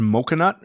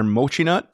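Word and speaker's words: Mochinut: But yeah, it is is it pronounced mocha nut or mochi nut? But it's mochi and donut, which I don't even know what mochi is, but they were pretty good.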